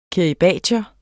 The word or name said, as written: kiribatier